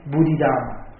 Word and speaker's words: Bodhidharma: You used to hear his name in Vietnamese, Bồ Đề Đạt Ma. But in the Pali and Sanskrit pronunciation, we call him, his real name, Bodhidharma.